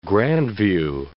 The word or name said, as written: Grandview